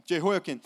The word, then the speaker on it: Jehoiakim: Jehoiakim, right? And I, I think I'm pronouncing that correctly. Jehoiakim. P. Sid, can, is that correct? Okay. Jehoiakim, who killed, this is, this is one of the things he's known for.